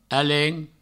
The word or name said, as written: alen